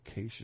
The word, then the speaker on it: Kasich: In addition, former Arkansas Governor Mike Huckabee, Ohio Governor John Kasich, is how you say his name, Arizona Senator John McCain, and Florida Governor Rick Scott will also have prime time speaking slots.